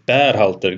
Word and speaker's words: Berhalter: Beer är det inte utan det är Berhalter. Det är ett ah, E.